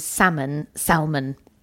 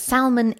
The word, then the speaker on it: salmon: Anyway, so sometimes I'll say salmon and Nico is convinced that salmon is the correct pronunciation. – Because it's got an L in it.